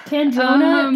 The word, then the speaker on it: Candrona: Firstly, I thought it was pronounced Cadrona. – There's an N. – I speed read, so I, like, mispronounce things in my head a lot. – Candrona, Candrona is a very, like, impractical word to say.